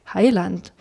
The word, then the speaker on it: Heiland: Aber das Land reimt sich nicht, also die natürliche Betonung ist ja Heiland.